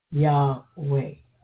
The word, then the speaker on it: Yahweh: Why would I use the J when we even have a Y in our alphabet? We can very easily pronounce that. Yahweh.